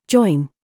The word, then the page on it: CHOICE: British English